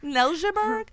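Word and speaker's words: Neltjeberg: Neltiberg? And the way they say it like that is because it's spelt like with an N-E-L-T-J-E. So it's Danish origins with that name.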